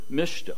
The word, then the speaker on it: MSCHF: Well, those are the consonants to the word mischief. And the company's name is Mischief, if you were to pronounce it.